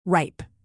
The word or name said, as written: ripe